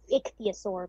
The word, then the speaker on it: ichthyosaur: Is that pronouncing it right? – It's ichthyosaur, but ichthyosaur. It's pretty close.